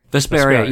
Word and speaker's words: Vesperia: Did I say that right? – Vesperia. Yep, the definitive edition.